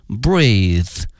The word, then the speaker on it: breathe: 同时啊，呼吸这个词因为呢它的词性是名词，因此它的发音是 breath，而它的动词呢是 breathe， breathe。